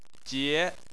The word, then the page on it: jie2: chinese